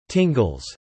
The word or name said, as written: tingles